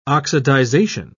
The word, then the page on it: oxidisation: British English